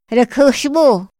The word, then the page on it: ra kjaxmo: Otomí